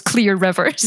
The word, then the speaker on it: Clear Rivers: Everyone thinking for 20 years that I think it was someone on Twitter that was saying, I thought her name was Claire. I just thought they were pronouncing Claire funny. I didn't realize she was Clear Rivers.